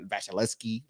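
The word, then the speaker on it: Vasilevskiy: Vasilevsky.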